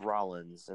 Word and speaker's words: Rollins: But it's Rollins, and that's the way to say it.